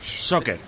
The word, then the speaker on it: Soccer: Soccer. Vale. Que había entendido este oyente, tal y como lo habíamos pronunciado, la O por la U, World Soccer Congress, y eso es otra cosa.